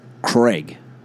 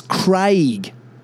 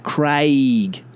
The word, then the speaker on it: Craig: But when the Americans say it, they say Craig, Craigslist, Craig Jones. Like what? C-R-E-G-G, Craig. Sounds like a fucking caveman's name. Craig. No, it's Craig. A-I, Craig. Craig, if you're English, it's Craig.